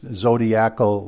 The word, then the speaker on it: zodiacal: Some people call it zodiacal, but believe me, I understand Greek and Latin, and it's zodiacal light is the way it's pronounced.